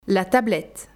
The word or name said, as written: La tablette